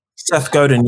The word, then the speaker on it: Seth Godin: And I'm actually not sure like where I got the ideas from, but maybe like Seth Godin. I'm actually not sure if I pronounce his name right. – Seth Godin, yeah.